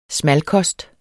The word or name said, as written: smalkost